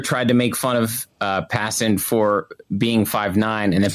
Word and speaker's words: Passan: ESPN's Jeff Passan. Is that how you pronounce it? – Passan.